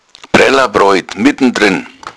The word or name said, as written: brellabroid